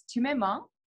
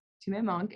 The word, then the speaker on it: Tu Me Manques: Yeah, it's Tu Me Manques. To my monk, it's French.